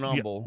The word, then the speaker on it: Humble: But when you're around here, you don't pronounce the H for whatever reason. Even though if you were saying the word humble, you would say humble.